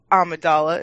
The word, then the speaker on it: Amidala: Senator Amidala.